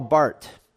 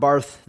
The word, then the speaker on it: Barth: You have the view of Karl Barth. It's not Barth. You don't really pronounce the H.